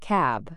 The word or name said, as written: cab